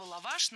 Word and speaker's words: лаваш: А вот слово лаваш на самом деле со звездочкой было раньше. Дело в том, что буквально год назад норма требовала ставить ударение в этом слове всегда на второй слог и говорить лаваша, лаваши, лаваши.